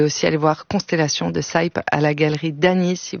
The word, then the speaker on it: Saype: Ça s'écrit Saype. Vous pouvez suivre ça Saïp sur ses réseaux.